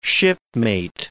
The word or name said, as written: shipmate